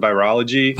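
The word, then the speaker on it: ViroLIEgy: Oh, I just pronounce it virology. Virology. But I've heard other, you know, like virology, or I think Dr. Tom Cowan has like 10 or 12 different pronunciations for it.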